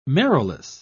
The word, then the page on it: marrowless: British English